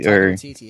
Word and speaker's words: Taika Waititi: And we got, uh, I can't pronounce his name very well, but Taika Waititi? – Taika Waititi, yeah.